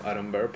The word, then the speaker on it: Aaron Burr: En la segunda canción, él conoce a Aaron Burr, o Aaron Burr, o no sé, yo le digo Aaron Burr porque así es como se pronuncia.